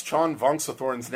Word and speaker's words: Xan Vongsathorn: Well, the segment is called How to Pronounce Xan Vongsathorn's Name. – Right. – And this is the first episode of that. – Right. – I'm going to start a new podcast called How to Pronounce Xan Vongsathorn's Name.